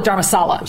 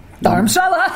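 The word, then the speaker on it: Dharamshala: There is a local pronunciation. We had always called it Dharmashala. – Oh, no, I'd call it Dharmasala. – Sala. – Yeah. Which is living room in Spanish. – Yeah, so it's not the divine living room. – It is. – In some ways it was. – However, the locals pronounce it a little differently.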